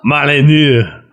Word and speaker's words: Molyneux: Stephen Molly meme. – It's pronounced Stefan. Oh, Molyneux.